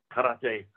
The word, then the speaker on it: karate: K-A-R-A-T-E. What is karate? Because it's K-A-R, how you pronounce it. And he says, no, that's karate. I said, what? I never rolled an R in my life.